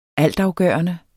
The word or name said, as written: altafgørende